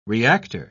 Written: reactor

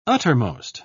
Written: uttermost

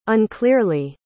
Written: unclearly

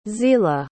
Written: zelah

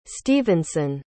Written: stephenson

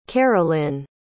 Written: carolynn

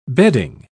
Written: bedding

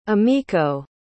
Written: umeko

